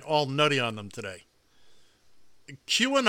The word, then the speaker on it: QAnon: Is that how it's pronounced? – QAnon, yeah.